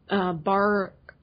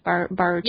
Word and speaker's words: Baruchel: Jay Baruchel. – Thank you. – Baruchel.